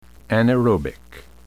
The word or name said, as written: anaerobic